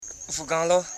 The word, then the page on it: fuganlo: fu.gan.lo